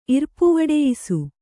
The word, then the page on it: ಇರ್ಪುವಡೆಯಿಸು: kannada